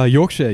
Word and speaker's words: Yorkshire: And did I pronounce that right, by the way? – Yorkshire, yeah, Yorkshire. – All right, cool. – Definitely. Not Yorkshire.